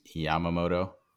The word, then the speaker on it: Yamamoto: I was saying Yakamoto instead of Yamamoto.